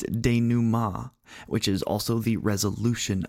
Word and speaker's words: denouement: Yes, I did have to have Google tell me how to say that because it is spelled D-E-N-O-U-E-M-E-N-T. So if you're trying to sound smart, but you've only read that word and you're like, the denouement, that's not right at all.